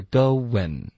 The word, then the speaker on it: going: 来、所以这里我们的 you were、没有变化，可是 going Joey 的这个发音方式呢，他说的是 going、 okay? 首先有一个 would 的音添加进去，结尾呢 n 的音舌尖要顶舌顶上颚 ,OK, 所以是 going,OK,、 okay?